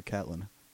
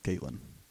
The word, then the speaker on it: Catelyn: See, I speak English, or American, I suppose, and when there's one consonant between two vowels, the first one's long, so I don't care if people call her Catelyn. It's pronounced Caitlyn.